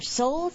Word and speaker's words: Seoul: These are soul side wings, and the soul side is spelled S-E-O-U-L.